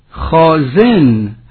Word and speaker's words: خازن: خازن حتما میخوانیم، زیرا عرض کردیم بر وزن فاعله، هر فاعلی باید بر وزن خود تلفظ شود.